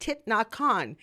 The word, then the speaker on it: Thich Nhat Hanh: Thich Nhat Hanh.